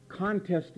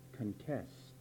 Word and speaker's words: contest: See, contest is one word, but it's pronounced two different ways. Contest is the noun. Let me tell you another way to pronounce it, which is the verb, contest.